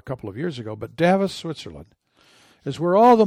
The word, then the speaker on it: Davos: Okay, Davos, Switzerland. I have said DeVos most of my life because I grew up around Grand Rapids, and Van Andel and DeVos were the two founders of Amway.